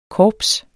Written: korps

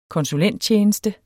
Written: konsulenttjeneste

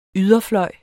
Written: yderfløj

